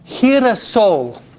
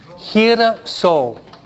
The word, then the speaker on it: girasol: Girasol. Girasol.